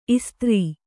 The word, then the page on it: ಇಸ್ತ್ರಿ: istri